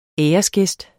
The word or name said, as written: æresgæst